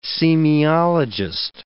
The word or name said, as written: semiologist